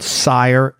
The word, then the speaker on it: Cire: One is called Sire, but it's spelled C-I-R-E.